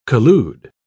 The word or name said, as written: collude